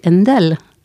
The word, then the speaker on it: Händel: Oui, alors que bon, si on regarde le cas de Händel, on a aussi à un moment donné orthographié H-A-E, mais on a toujours prononcé Händel.